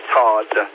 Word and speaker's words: Todd: It's funny, a lot of times people, I guess I don't pronounce the D too well on the radio because I get a lot of, are you Tom? That's Todd with a D.